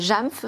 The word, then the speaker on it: JAMF: On en parle donc avec qui est en charge de l'éducation et de la mise en place des projets JAMF, c'est comme ça qu'on prononce Chez JAMF, tout à fait, ouais.